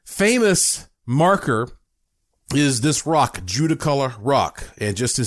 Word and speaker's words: Judaculla: Warren, and David Weatherly brought up Judicula, and Judicolor Rock. Now, that is spelled J-U-D-A-C-U-L-L-A.